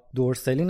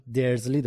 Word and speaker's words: درزلی: تلفظات دقیقا مثل نسخه بریتانیاییه یعنی ما دیگه هرمیون نداریم هرماینی داریم یا دورسلی نداریم درزلی داریم و